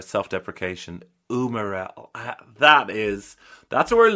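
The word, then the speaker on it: umarell: But if not, umarel, Italian pronunciation, umarel,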